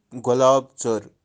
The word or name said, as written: golaab-tser